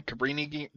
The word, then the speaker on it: Cabrini: in the Kabini, I'm gonna say this wrong. Cabini Green, Cabrini Green.